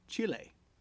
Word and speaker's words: Chile: Now that being said, the transgression of which I ran afoul today was that I said Chile.